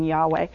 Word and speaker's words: Yahweh: We think that the pronunciation would have been Yahweh.